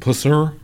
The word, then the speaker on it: Pusser: Am I saying his name wrong? – Pusser. – Is it Pusser? – Pusser.